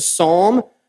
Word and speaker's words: psalm: It's a psalm pronounced with a S, not with a palm. It's not the palmist, Mr. Biden.